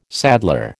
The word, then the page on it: saddler: American English